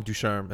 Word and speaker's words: Ducharme: Dom Ducharme, assistant coach of the Montreal Canadiens.